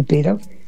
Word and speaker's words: Pere: Pedro es pera. Se dice pera, pero se escribe Pere.